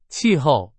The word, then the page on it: 气候: Chinese